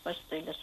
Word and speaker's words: пастельное: Все-таки «э» оборотное должно звучать, пастельное слово.